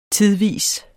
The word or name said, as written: tidvis